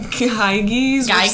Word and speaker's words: Gyges: So Kiddos, Briarios, and, Giges? – I was thinking Gyges. Which sounds really stupid. – No, Gyges sounds better than Giges.